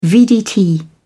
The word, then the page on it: vdt: British English